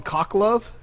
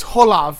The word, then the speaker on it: Khoklov: And his last name was Khoklov, and I always called him Cocklove. And he was like, "It's Holov. The K is silent." I said, "No, you're in America. The H is silent."